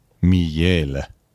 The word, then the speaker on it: miele: Miele wird es geschrieben. Ausgesprochen wird es natürlich Miele.